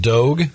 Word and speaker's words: Dogue: We have this other story about this restaurant in San Francisco called Dogue. It rhymes with Vogue.